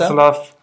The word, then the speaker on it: Václav: El Tumor, Prinz Vaclav von Oettinger, habe ich das richtig ausgesprochen? – Äh, Vaclav, Václav, heißt Václav, wie auch immer.